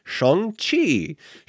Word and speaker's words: Shang-Chi: you're going to mispronounce it, so trust me, the way this is pronounced is Shang-Chi and the Legend of the Ten Rings.